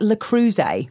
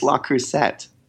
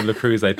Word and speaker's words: Le Creuset: How did you pronounce that? – Le Creuset. – How do you pronounce it? – I think it's La Creuset. – Babe, it ain't. – It may not be, but it's definitely not Le Creuset.